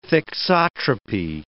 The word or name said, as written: thixotropy